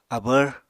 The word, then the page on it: abvr: Apatani